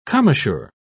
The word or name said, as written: commissure